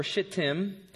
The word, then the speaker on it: Shittim: Verse 1, early in the morning, Joshua and all the Israelites set out for Shittim, rhymes with Hittim, I don't know, and went to the Jordan where they camped before crossing over.